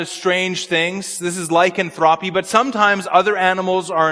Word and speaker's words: lycanthropy: It's called, and I don't know how to pronounce this name, but it's called lycanthropy. Lycanthropy. Somebody just confirmed my pronunciation. Thank you, brother.